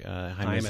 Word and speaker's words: Haim: Haim as in Anaheim? – Yes, Anaheim.